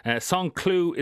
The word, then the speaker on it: Saint-Cloud: Saint-Cloud is how you pronounce it. Saint-Cloud is how it would look like if you're reading the newspaper or checking any website. But Saint-Cloud, it is called, and he's got some big hopes there.